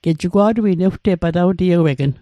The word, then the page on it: patawtiewegn: Mi'gmaq